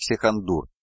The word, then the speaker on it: Искандар: На низком готике Уральской области Терры Искандар произносят как Сихандур, а Хайон как Каин.